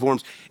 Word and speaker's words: Worms: It looks like the Diet of Worms, but it's pronounced V, Diet of Worms.